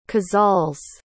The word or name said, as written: casals